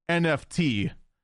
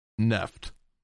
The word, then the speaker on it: NFT: I hate to remind you, both twits in the chat and also The Verge, it's pronounced NFT, not Neft. For those who don't get the joke, last week, The Verge put out an article trying to say that Neft, N-E-F-T, is how you're supposed to pronounce NFT.